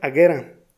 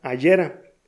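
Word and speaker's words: Agera: Luego de unos minutos de búsqueda te das cuenta que no se pronuncia Ayera, tampoco Ajera, queda como aguera.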